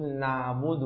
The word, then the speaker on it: نعبد: يعني أخي حبيب نطقك لحرف العين فيه صفة شدة، فحاول أن تقول نعبد نعبد نعبد بهذا النطق. كذلك بحت الحاء في الرحيم وما شاكلها من الحاءات، حاول أن تزيد من صفة البحة أكثر قليلا فتقول الرحمن الرحمن الرحيم الحمد الحمد.